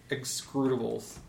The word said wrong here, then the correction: execrables: Wait, no. E-X-E-C-R-A-B-L-E-S. Excrables. – I need you to spell that again. – E-X-E-C-R-A-B-L-E-S. – Excrables. – Excrables. Exemplary excrables. Execrables?